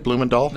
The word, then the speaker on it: Blumendahl: Blumendahl. Did I say it right? Blumendahl? – That's right. – Dahl or Dale? Dahl. No, it's Dahl, yeah, because there's no E.